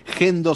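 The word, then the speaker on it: Hendo: Vayan a YouTube, pongan Gendo, se escribe con H, Endo, Gendo.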